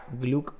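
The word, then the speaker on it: glögg: Y en, países nórdicos se le llama glog, que se pronuncia gluk.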